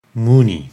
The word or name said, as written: Muni